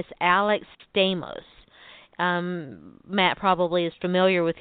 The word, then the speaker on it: Stamos: I mean, it's spelled just like John Stamos, so yeah. I assume that it's pronounced that way.